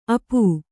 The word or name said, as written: ಅಪ